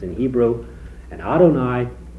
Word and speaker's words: Adonai: Capital L, but then O-R-D in small letters. And the King James and the RSV are very consistent. You pronounced it very well, Adonai,